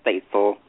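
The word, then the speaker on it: Stacil: My name is Faithful. It's like, I have to explain to everyone. It's like Stacy and Pencil together.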